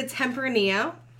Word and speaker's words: Tempranillo: It's a Tempranillo. – Spell that. – T-E-M.